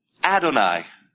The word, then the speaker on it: Adonai: The Lord, this word is Adonai.